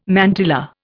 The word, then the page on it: mantilla: Indian